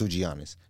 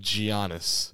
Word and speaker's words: Giannis: So I can't help but notice you're putting that hard G on Giannis. – Yeah. – You know, the Greeks, they pronounce it Giannis.